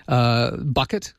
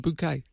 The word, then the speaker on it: Bouquet: Bucket? But it's pronounced Bouquet.